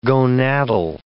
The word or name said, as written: gonadal